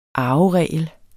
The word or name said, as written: arveregel